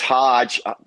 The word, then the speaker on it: Hoge: It's Hodge, pronounced like Dodge, but no D in it.